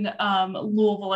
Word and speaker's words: Louisville: Oh man, you know, growing up in Louisville, as we say it, you have to really not articulate to say it correctly. Half choke on it a little bit, Louisville.